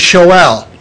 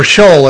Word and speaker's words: Sheol: Old Testament hell is Sheol, or Sheol as many people pronounce it.